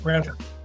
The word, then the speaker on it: Branford: Did I say that right? – Yeah, Branford.